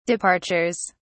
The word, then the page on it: departures: British English